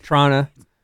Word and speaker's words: Toronto: I pronounce the second T so much so. – Yeah, it doesn't get pronounced. Toronto.